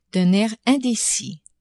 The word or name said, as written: d'un air indecis